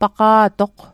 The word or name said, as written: paqa'toq